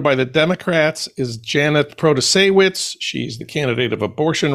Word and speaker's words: Protasiewicz: Well, I think it has become a nationalized race because hosts of national podcasts can pronounce Protasiewicz.